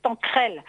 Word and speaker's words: Tanquerel: Tanquerel, en fait il l'appelle Tanquerel, ça s'écrit Tanquerel, mais on dit Lise Tanquerel.